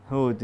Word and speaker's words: Hud: It's three letters in Arabic language. Starts with Ha. No, three letters, man. It rhymes with food. Hud, yeah, okay.